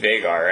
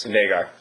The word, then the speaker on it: Vhagar: The next one, I would say Vhagar, right? – Yes. – Vhagar.